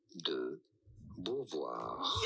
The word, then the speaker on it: Simone de Beauvoir: How do you go about pronouncing her name? In French, in France, it is said as Simone de Beauvoir.